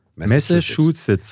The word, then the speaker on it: Massachusetts: Genau, pass auf, kleiner Trick. Messe, Chu, Sitz. Massachusetts.